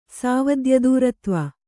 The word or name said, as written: ಸಾವದ್ಯದೂರತ್ವ